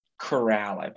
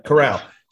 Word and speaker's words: Corral: Is it Corral or Coral? I never know how to pronounce his last name. – Corral. – Corral.